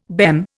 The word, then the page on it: BEM: Indian